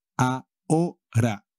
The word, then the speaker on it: ahora: Ahora. Now. Y como siempre, la H no se pronuncia nunca en castellano.